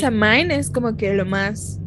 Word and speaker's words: Samhain: Yo, bueno, yo escuché a un este a un irlandés, lo busqué en YouTube cómo lo pronunciaba y lo pronuncia Samwin.